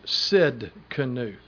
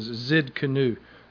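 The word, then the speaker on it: Tsidkenu: The spelling is T-S-I-D-K-E-N-U. T-S-I-D-K-E-N-U means the Lord our righteousness. It's pronounced either as Zid with a Z sound or as Sid Kanu. Most of the time, most Hebrew scholars pronounce it as Zid Kanu, Jehovah Zid Kanu.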